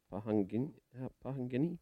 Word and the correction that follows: Pohangina: Cup? How do I say that? – Pohangina. – Yes. – Pohangina Cup. – Yeah. – Pohanga. No, yeah, Pohangina Cup. There you go.